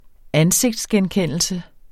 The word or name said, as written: ansigtsgenkendelse